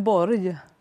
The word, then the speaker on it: Borg: So the great tennis player Björn Borg, in Swedish, how's his surname pronounced then? – Björn Borg. – Borg.